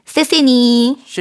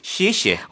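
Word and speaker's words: 시에시에 (谢谢): 대부분 이렇게 발음합니다. 세세니. – 세세니. 근데 이거는 성조가 없는 거야. – 그리고 저는 학생들이 세세니 하면 대답 안 해요. 세세는 없어요, 중국어. – 세세는 발음이. – 없는데 보통 네. 이거 우리가 이게 건너 건너 듣다 보니까 네네. 세세라고 들리는데 사실은 정확하게 시에시에.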